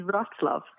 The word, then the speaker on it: Wrocław: Wrocław, is it? – How you pronounce it is Wrocław.